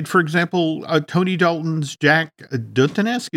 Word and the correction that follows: Duquesne: is is that how we, we pronounce his name? Duquesne. Duquesne, uh, Jack Duquesne.